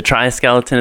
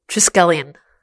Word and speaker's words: triskelion: So, the tri-skeleton is being built. – Oh, which my web guy told me we are totally mispronouncing. – Are we? – Yeah. – How's it pronounced? – Like, triskelion.